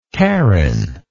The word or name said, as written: caryn